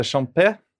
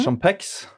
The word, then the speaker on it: Champex: Ja, vi skal overnatte i en by som heter Champé, eller mm -hmm. Champex. Mm -hmm. – Begge uttalene uh, brukes.